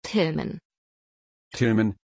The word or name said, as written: Tillman